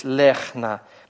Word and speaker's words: лехна: Вот как здесь лехна. Мы видим, что цере – это долгий гласный, долгое е.